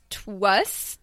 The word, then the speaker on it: TWS: And there is, it's spelled TWS, but we believe it's pronounced Twas, to us.